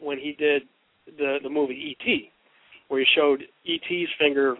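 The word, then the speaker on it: et (את): In Hebrew, that would be pronounced et.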